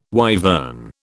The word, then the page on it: wyvern: British English